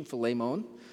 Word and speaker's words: Philemon: That's the correct pronunciation, Philemon. I hope it doesn't make you hungry.